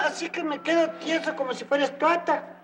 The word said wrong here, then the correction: estatua: No se dice estuata, se dice menumento. – Pero qué mal están los dos. Ni se dice estuata, ni se dice menumento. Se dice monumento o estatua.